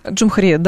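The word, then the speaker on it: Cumhuriyet: Вот газета, не знаю, правда, как, простите, читается правильно, Камхарет, видимо, так. – Джумхарет, да.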